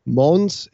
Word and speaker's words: Mons: And the S is pronounced, according to my French friend, Mons.